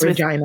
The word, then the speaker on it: Regina: Is that how you say it? – Yep, rhymes with perfect. rhymes Regina.